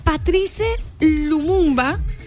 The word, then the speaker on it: Patrice Lumumba: Es un homenaje que él, como estatua viviente, le brinda a Patrice Lumumba. No sé si lo estoy pronunciando bien, me disculpa. No, estás pronunciándolo bien, pero dale.